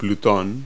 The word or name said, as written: pluto